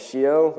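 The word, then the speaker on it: Scio: So Shio, I've heard of many different pronunciations. The way we like to call it is Shio, with the full, you know, hand gesture.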